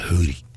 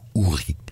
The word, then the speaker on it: houri: Vous avez sans doute remarqué que je prononce Hurry avec l'accent algérien plutôt que Houri. En Algérie et dans beaucoup de pays du Maghreb, on prononce bien Hurry avec un H plus marqué.